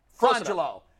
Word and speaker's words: Frongillo: Is it Frangillo? Is that how you say it? – Frangillo.